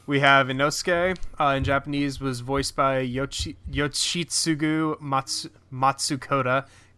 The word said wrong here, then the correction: Matsuoka: God, he's got two really difficult to pronounce names. – If you want to correct me? Yoshitsugu Matsuoka.